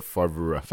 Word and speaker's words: Favre: which is not the way you pronounce it. Mm-hmm. So here in the movie, he'll be like, Brett Favre.